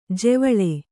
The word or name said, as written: ಜೆವಳೆ